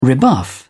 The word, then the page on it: rebuff: British English